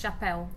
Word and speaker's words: chapéu: And so, and how do you pronounce chapéu? – Chapéu.